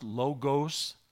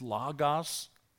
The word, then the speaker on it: Logos: It's not pronounced Logos. Those are not long O's in the original language. It's Logos.